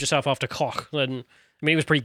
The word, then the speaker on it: Koch: Koch. Is that how you pronounce it? Koch.